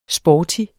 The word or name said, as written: sporty